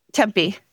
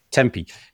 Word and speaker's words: Tempe: Tempe. – Tempe.